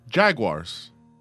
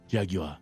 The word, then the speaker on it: Jaguar: Jaguars.